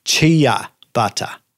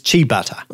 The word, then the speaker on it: ciabatta: Apparently, the way it is mispronounced the most is chia butter. Chia okay. butter. But it is chi butter.